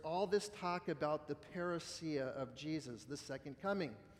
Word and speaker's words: parousia: The coming of Jesus, or better said, the second coming of Jesus, is encapsulated in a Greek word called, pronounced parousia, parousia.